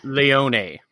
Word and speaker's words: Leone: Captain Boons Leon, I think. Did I pronounce that right? Or is it Leon? – Leone. – Leone. – Which is very fancy. It's Italian for lion.